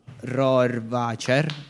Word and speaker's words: Rohrwacher: cioè Margherita Bui e Alba Rohrwacher.